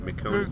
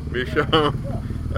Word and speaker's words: Michonne: Michonne. you can't pronounce with a taco in your mouth either. Michonne.